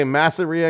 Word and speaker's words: Masseria: I'm going to say Masseria because it rhymes with diarrhea.